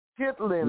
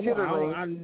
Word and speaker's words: chitterlings: It's pronounced chitlin. – No, not chitterlings.